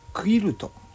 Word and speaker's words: quilt: ー、ね、えー、あれですで、えもう一個のキルトこれはねあの日本で言うと文字で書くとねキルトなんですけど英語の文字で書くと Q から始まるんですね QUILT なんですよね QUILT でクイルト